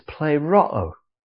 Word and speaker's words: pleroo: Now, in verse 25, the word fulfill is pleroo in the Greek. P-L-E-R-O-O. And there's a long O in there somewhere. Pleroo.